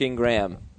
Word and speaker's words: Ingram: That's how you pronounce it, right? Ingram? – Mark Ingram. Yeah, like Mark Ingram. Mark, he's the Marking Ram.